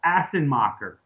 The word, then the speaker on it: Assenmacher: And it's goofy to say, but my honorable mention will be Paul Assenmacher. Did wow. – I say that right? – You did. – Good old Assenmacher. – Well, maybe it's a little softer on the S.